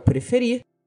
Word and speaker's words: prefer: Mas, além de stop, que se torna stopping, temos o caso também de prefer, que é o preferir, que no caso possui duas sílabas, pre e fer, mas a sílaba tônica desse verbo, ou seja, a sílaba mais forte e que é pronunciada de forma mais intensa, é a última.